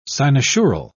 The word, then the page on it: cynosural: British English